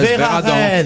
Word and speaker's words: Verarden: Non, ça s'écrit Verarden, mais moi je prononce à la française Véradan.